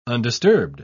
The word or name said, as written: undisturbed